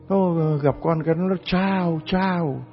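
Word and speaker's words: ciao: Ông cha bề trên cũng cười quá trời, ông bảo không, đó là cái chữ tiếng Ý nó chiao chiao chiao á, C-I-A-O, nó phát âm là chào chào, là tiếng Ý chứ không phải là tiếng Việt Nam là chào chào đâu.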